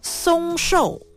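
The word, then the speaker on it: 嵩寿: 日本最长寿的老人鹤川滋子刚在上个月 27号逝世，享松寿 115岁。松寿，松的读音和松树的松相同，不过写法是上面一个山，山丘的山，下边是高，高度的高，身高的高，这个字念松。松寿是什么意思呢？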